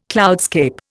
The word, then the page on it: cloudscape: Indian